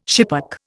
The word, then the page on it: schipperke: Indian